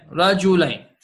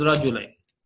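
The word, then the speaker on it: رجلين: Rajulain. The shutdown on the R is just for the pronunciation, the tajweed, huh? In reality, this is Rajulain.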